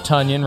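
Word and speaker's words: Tonyan: Robert Tunyon rhymes with Funyon.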